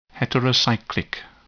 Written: heterocyclic

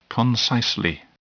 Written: concisely

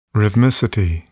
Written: rhythmicity

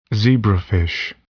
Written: zebrafish

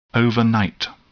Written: overnight

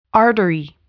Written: artery

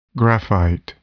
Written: graphite

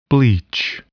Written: bleach

